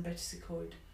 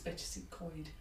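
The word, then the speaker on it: Betws-y-Coed: So I think it's Bettisacoid. I might always say Bettisacoid, and he says it's Bettisacoid.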